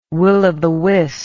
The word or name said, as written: will o the wisp